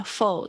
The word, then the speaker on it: full: 然后这边要特别注意一下 “full” 这个发音，full，full。否，哦，千万不要念成负哦，负哦，就会变成另外一个字傻瓜。它是有一个那个，它这边的 u 是呃呃呃的发音，for 哦，它不是呜的这个声音。负呜哦，负哦是讲那个 f o o l 那个才是负哦哦，所以有时候有些同学会念的不 OK。